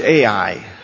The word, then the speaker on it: Ai: It's spelled Ai, and it's pronounced Ai.